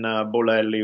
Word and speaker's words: Bolelli: Yeah, so it's at the first initial of my first name, so the letter D, and then Bolelli, which is spelled B-O-L-E-L-L-I.